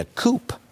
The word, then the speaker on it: coup: People who believe that, I think, would call it a coup. They're not even smart enough to understand the P is silent.